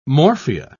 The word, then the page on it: morphia: British English